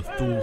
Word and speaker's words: Thule: And they were sort of the inner circle of the Thule Society, or the Thule Society, however you prefer to pronounce that.